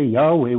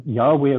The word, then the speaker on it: Yahweh: On two occasions, the name of Yahweh appears, but with a J and not with a Y. – Yeah, still pronounced like a Y.